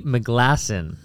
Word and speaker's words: McGlasson: My guest today is Ed Tandy McGlasson. Did I say that right? – Dude, you are in.